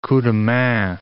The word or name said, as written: coup de main